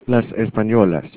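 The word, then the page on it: españolas: Spanish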